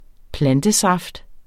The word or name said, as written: plantesaft